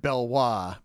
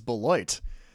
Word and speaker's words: Beloit: I think I heard Belois. Belois, of course, is Beloit. Other